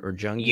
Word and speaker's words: Jungian: Union or Jungian? – Jungian. Yeah, because it's Carl. Okay. It's Carl Jung.